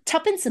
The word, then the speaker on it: Tuppence: But I do have to admit, I love Tuppel, Tuppis, Tuppel, Ack, ah, not say her name, um, Tuppence Middleton's performance, who's also doing soft spoken in this as well.